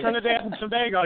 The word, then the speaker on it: Trinidad and Tobago: A country named what is it, Trinidad and Tobago? I don't even know how to pronounce it. Yeah, Trinidad and Tobago, yeah.